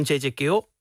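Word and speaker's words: J.J. Cale: Call Me The Breeze van J.J. Kill.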